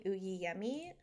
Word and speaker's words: Oyeyemi: And it is by Helen Uyiyemi, which is spelled O-Y-E-Y-E-M-I, Uyiyemi.